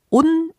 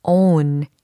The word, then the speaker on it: own: 어, 특히 발음이요. on 이게 아니라 own. 이와 같이 처리하셔야 되겠습니다.